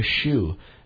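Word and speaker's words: Sjöö: So, anyway, Monica Hsu, and that's spelled S-J-O-O. Monica Hsu, she was awesome, and here's to her, all right?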